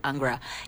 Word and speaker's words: Angra: I'm saying Angra or Angra, so in Portuguese we say Angra, and I'm saying Angra, so you know what the band is.